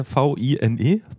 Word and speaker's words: Vine: Und zwar Vine, hier Vine, wird das so ausgesprochen? – Ja.